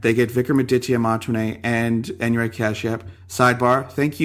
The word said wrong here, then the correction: Vikramaditya Motwane: No, Vikram Aditya Mantwane. Oh, Vikram Aditya Mantwane.